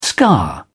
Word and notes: scar: British English

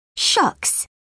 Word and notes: shucks: British English

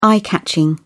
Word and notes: eye-catching: British English

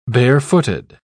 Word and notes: barefooted: British English

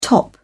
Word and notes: top out: British English

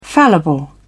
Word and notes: fallible: British English

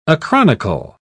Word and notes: acronychal: British English

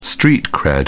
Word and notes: street cred: British English

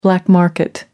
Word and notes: black market: American English

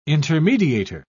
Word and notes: intermediator: British English

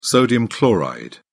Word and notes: sodium chloride: British English

